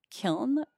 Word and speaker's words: kiln: like the way that kiln was initially pronounced was kill. And so there's that mm-hmm. silent N at the end of it. And so I was reading that like when there's that sort of silent N, what happens over time is that that tends to morph into a word where the N is in fact pronounced, just because of these like consistent mispronunciations of it becoming so ubiquitous that like we all just end up accepting the mispronunciation as the actual pronunciation.